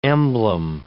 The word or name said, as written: emblem